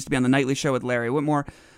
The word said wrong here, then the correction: Wilmore: Wilmore, right?